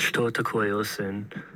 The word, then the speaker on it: Что такое осень: Up next, we have a song from the famous Russian band DDT from 1992, and the song is called Что такое осень, which translates to What is Autumn in English.